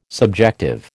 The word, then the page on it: subjective: American English